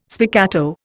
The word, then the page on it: spiccato: Indian